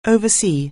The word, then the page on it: oversee: anglais